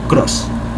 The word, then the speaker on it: Krause: Klein se escribe K-L-E-I-N, Cross se escribe K-R-A-U-S-E, Cross.